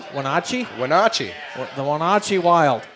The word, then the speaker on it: Wenatchee: We come back how the hell do you pronounce the name of that team? – Wenatchee. The Wenatchee Wild.